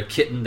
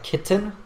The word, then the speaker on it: kitten: And I noticed you said we pronounce that word a little bit different. So you said like kitten, and I say kitten. – With sure. – That glottal T, kitten.